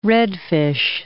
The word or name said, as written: redfish